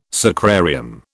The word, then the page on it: sacrarium: British English